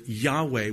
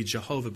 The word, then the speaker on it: Yahweh: The Lord, Yahweh, would be the way to pronounce it, actually. The popular way to pronounce it would be Jehovah, but probably the more accurate way is Yahweh.